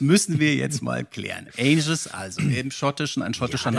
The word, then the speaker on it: Angus: Es ist falsch ausgesprochen, Andreas. Mhm. Es ist Angus.